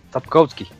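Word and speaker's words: Sapkowski: Zapkowski.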